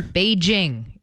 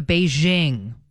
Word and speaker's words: Beijing: Beijing is how it's pronounced. – That's the hard J sound. – The hey? – Hard J, not the Beijing, which is how I've said it entire life.